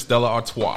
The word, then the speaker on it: Stella Artois: If you're in Belgium, it's Stella Artois. – Artois. – You're in the States, Stella Artois, or just Stella. – That's Stella Artois.